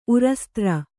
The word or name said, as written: ಉರಸ್ತ್ರ